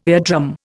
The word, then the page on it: eardrum: Indian